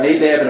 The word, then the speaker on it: Nadab: If you did not know, Leviticus 9 and 10 are basically they're a 2-week look into how Moses was to ordain Nadab. Am I pronouncing that right? Yeah, Nadab and Abihu.